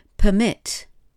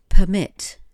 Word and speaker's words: permit: Permit, permit, to allow, to let. And it's almost like the same word as permit, but it is pronounced differently. permit is something quite different, but to, to permit, the verb, is to allow or to let.